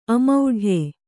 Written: ಅಮೌಢ್ಯ

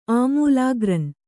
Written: ಆಮೂಲಾಗ್ರಂ

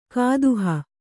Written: ಕುದಿಹ

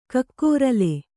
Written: ಕಕ್ಕೊರಲೆ

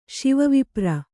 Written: ಶಿವವಿಪ್ರ

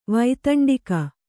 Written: ವೈತಂಡಿಕ

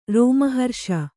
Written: ರೋಮಹರ್ಷ

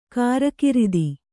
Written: ಕಾರಕಿರಿದಿ

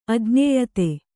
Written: ಅಜ್ಞೇಯತೆ

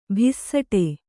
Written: ಭಿಸ್ಸಟೆ

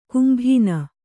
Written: ಕೆಂಬೊನ್

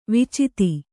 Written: ವಿಚಿತಿ

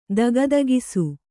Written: ದಗದಗಿಸು

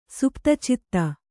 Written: ಸುಪ್ತಚಿತ್ತ

